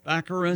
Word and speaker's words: Baccarin: Baccarin? – Baccarin.